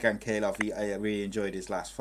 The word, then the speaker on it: Ankalaev: The Russian, I can't say his name, but Ankalaev.